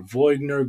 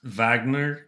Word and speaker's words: Wagner: So the whole crew got together, and we've been seeing all this news about Wagner Group or Voigner Group or however it may be pronounced. And that's Wagner, even though they're Russian, not German.